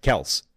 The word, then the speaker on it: Kelce: The Kelce, by the way, which might now actually be pronounced Kels.